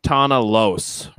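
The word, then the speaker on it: Tahna Los: Basically, it's this episode is about a guy named Tana Los, right? Did I say that right? I always kind of get it. – I believe that's how you would pronounce it in the original Bajoran.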